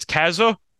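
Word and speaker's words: Cazaux: I might be butchering the pronunciation, but it is French, so I believe you don't pronounce the X. I think it's Kazu, but Kazu was able to get the job done in 4.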